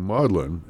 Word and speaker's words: Magdalen: A British pronunciation of Magdalene being maudlin, as in Magdalen College, but it's spelled Magdalene.